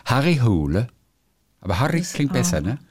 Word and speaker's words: Harry Hole: Und sein Kommissar wird übrigens ausgesprochen Harry Hole. Weil viele sagen Harry Hole oder Harry Hole, aber er wird ausgesprochen Harry Hole.